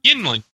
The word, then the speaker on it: Yuengling: This is it pronounced Yuengling or Yuengling or Yingling beer? – Yuengling.